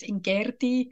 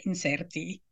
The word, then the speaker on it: incerti: Yeah, well, it depends on if you pronounce it in the classical Latin, it's incerti. – Okay. – But then if it's a later Latin, it's inserti.